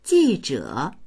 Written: ji4zhe3

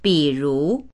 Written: bi3ru2

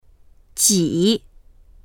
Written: ji3

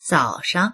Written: zao3shang

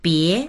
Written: bie2

